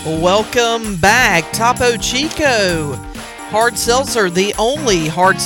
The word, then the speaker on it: Topo Chico: Topo Chico.